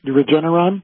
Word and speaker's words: Regeneron: We talked yesterday at length about a procedure with Regeneron, I believe. Am I saying that right? – Regeneron? – Oh, yeah.